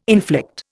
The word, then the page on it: inflict: Indian